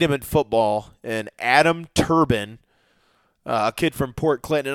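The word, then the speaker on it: Thorbahn: Because Thorban is how I would think, but his name is Adam Turbin.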